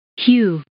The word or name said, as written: hew